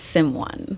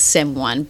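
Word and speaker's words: Sym1: So for people who don't know me, it's Sim One. – Like my artist name is Sim One, but for people who know me, it's just Simone. And it's spelled S-Y-M number one.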